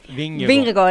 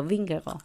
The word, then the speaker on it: Vingegaard: ¿Cómo se dice? – Vingegaard. – El otro día un seguidor de Twitter me estuvo explicando Vingegaard. – Los nombres daneses para mí son los más complicados porque nunca se dicen cómo se escriben.